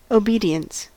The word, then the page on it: obedience: American English